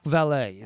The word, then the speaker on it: Vallée: It was added by Jacques Vallée is how you say his name.